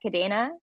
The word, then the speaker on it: Kadena: How do you say that? Kadena? – Yeah, that's right. Kadena.